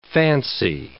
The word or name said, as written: fancy